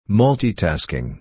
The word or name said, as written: multi-tasking